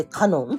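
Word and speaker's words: 神音: で、その神の声を聞けっていう言葉を神の音を聞けっていうふうに置き換えて、神の音と書いてカノンって読ませることにしたんです。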